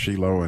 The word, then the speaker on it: Shiloh: Yeah, if you do go, again, it's pronounced Shiloh.